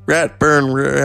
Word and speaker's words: Ratburn: The first episode of the 22nd series of children's program, Arthur features the character Mr. Ratburn. Sorry. Marrying his aardvark partner, Patrick. Okay. Interesting. Ratburn rhymes with rope burn.